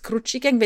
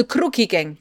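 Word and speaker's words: Crucchi Gang: CCH im Italienischen, Cappuccino, schreibt man mit zwei C, ne? – Ja. – Zucchini, also Crookie Gang, gut, okay. Zucchini, CCH mhm. ist ein K.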